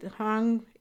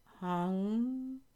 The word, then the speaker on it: hong: You write hung, H-O-N-G, but you pronounce U-N-G. And sa, S-A-U, sa.